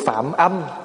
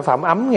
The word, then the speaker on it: phạm âm: Phạm âm chứ không phải phạm ấm nha. Đó, thí dụ một chữ vậy. Mà nếu mà người không biết thì cứ đọc là phạm ấm. còn mình điếc thì mình đọc là phạm âm.